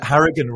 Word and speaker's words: Harrigan: Did you hear him say that Harrigan rhymes with arrogant?